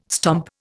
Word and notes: stomp: Indian

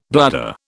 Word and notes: bladder: British English

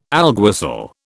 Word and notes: alguacil: British English

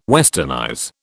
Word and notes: westernize: British English

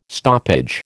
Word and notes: stoppage: American English

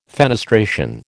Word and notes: fenestration: American English

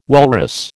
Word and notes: walrus: American English

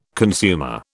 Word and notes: consumer: British English

